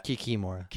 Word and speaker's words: kikimora: Is that how you say it? – Kikimora. – Kikimora. Damn it. – Take a sip. – Take a sip, everyone. So the Kikimora. – Correct.